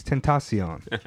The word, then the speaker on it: Tentacion: It's Tentacion. You gotta put that little bit of Spanish flavor on it. – Temptation. – I think he's Spanish of some sort. – It's not Tentacion.